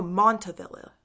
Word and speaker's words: Montavilla: Southeast is kind of hit or miss, so I don't know Montana Village, but Southeast is more industrial. – Oh, Montavilla. Sorry, I said that wrong.